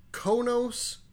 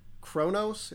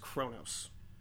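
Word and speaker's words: Qo'noS: Konos, but it's pronounced like Kronos. It's spelled Q-O-N-O, capital S. – Kronos. – It's pronounced Kronos.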